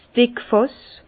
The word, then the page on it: 's Dickfàss: Bas-Rhin; Herrlisheim